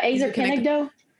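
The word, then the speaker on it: Ezer Kenegdo (עזר כנגדו): Well, you talked about the idea of an, I might be saying this wrong, but an Ezer Kenegdo. Did I say that right? – Yeah. Ezer Kenegdo.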